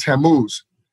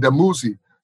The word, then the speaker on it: Tammuz: And that's because the cross was associated with the god Tammuz. This was the ancient Babylonian god who was also called Demuzi. So some pronounced it with the T, some pronounced it with the D.